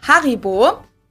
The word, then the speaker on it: Haribo: We pronounce it Haribo. Haribo. – Yeah, I'd say Haribo.